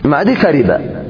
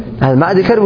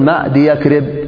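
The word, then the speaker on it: معديكرب: معدي كرب هو نفس المزجي. التركيب المزجي ممنوع من أي إذن. والنطق الصحيح هذا في معدي كرب نقول معدي كرب ولا تقول معدي يكرب خطأ.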